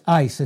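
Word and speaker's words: ice (εἰς): It's ice. It's pronounced like our word ice, I-C-E, but it's spelled E-I-S in the Greek, and this is what it means.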